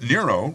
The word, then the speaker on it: Nero: Some people thought Nero had a good ancient ring to it, and noted that Nero rhymes with hero.